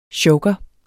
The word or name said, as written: chokere